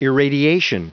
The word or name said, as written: irradiation